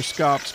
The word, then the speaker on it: scaup: So those are lesser scops grunting. If you've never heard the name scop, it's spelled S-C-A-U-P.